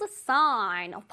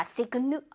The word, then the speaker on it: sign: 이것도 마찬가지로 sign 할 때 G 소리를 내면 안 됩니다. Signu 아니에요. Sign. Alright.